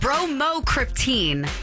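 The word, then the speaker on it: Bromocriptine: Brokryptonine? Bromocryptine. That's it.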